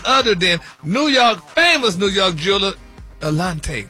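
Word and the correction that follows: Eliantte: Eliante. – Eliante? – Eliante. – Are they how you pronounce it?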